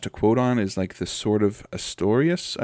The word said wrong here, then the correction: Artorias: i, I don't know if i'm pronouncing that right the the one that the wolf wields in uh, Artorias.